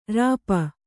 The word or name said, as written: ರೋಪ